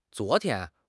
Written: zuo2 tian1

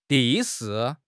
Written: 地势

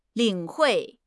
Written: 领会

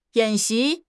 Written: 演习

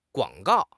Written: guang3 gao4

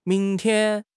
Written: ming2 tian1

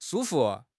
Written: shu1 fu5

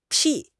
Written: 撇

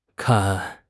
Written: kan1

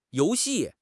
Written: you2 xi4

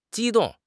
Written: ji1 dong4

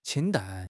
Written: qing1 dan4

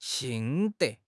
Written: 心得

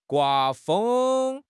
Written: gua1 feng1

